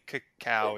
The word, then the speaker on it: Cacao: Cause there's that awesome scene where Beige comes back and they're like coming to Cacao Island or Coco Island to, how do you say that? Is it CACA? – I think Cacao is correct.